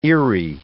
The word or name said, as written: Erie